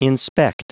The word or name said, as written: inspect